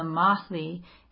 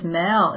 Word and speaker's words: Mahle: It's not just Mel, is it? Carla Mel? Is that how you're saying we say her name? Mahle.